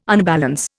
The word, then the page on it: unbalance: Indian